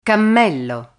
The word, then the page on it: cammello: Italian